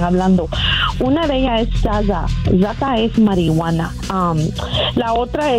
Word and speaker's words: zaza: Sí, so se pronuncia zaza, es Z-A-Z-A, zaza. – Zada. – Sí, es una palabra que Z-A-D-A, zada, zada.